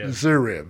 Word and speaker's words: Zverev: And Zverev? – Yeah, that is him. The V is silent. – Yeah. – Zverev. There you go.